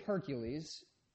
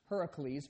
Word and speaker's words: Heracles: Like I said in the video, the story of Hercules, actually it's pronounced Heracles, but I hate to be the guy who corrects people's pronunciation.